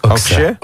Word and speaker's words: Okse: A na koniec Okse. Czy dobrze to wymawiam? – Tak, Okse. – Okse. – Okse. Dzięki wielkie.